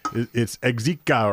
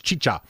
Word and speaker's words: Xicha: Beer, which is spelled X-I-C-H-A. We had fun with the spelling of it on the original podcast, because I was like, it's Exica or Zika. This one, it's Chicha.